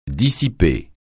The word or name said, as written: dissiper